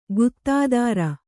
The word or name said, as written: ಗುತ್ತಾದಾರ